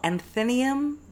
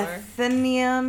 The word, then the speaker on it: Athenaeum: Anthenium? The Anth- I've never heard of that place before. Athenaeum.